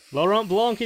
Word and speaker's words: Laurent Blanc: Laurent Blanc is correct.